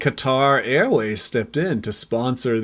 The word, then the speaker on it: Qatar: Somehow Qatar, which apparently is correctly pronounced Qatar. – Qatar.